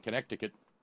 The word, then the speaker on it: Connecticut: Note coming in from Darlene in Connecticut, where they don't pronounce the C, but I do.